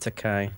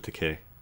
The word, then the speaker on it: Takei: I pronounce it Takei. – It's actually Takei.